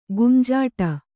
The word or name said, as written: ಗುಂಜಾಡು